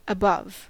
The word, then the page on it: above: American English